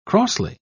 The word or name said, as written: crossly